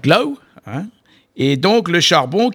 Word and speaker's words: glaou: Glau, oui, ça s'écrit G-L-A-O-U.